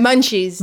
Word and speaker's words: munchies: Heb jij het over de Midnight munchi salade. – Moet je het zo uitspreken? Munchies. – Munchie.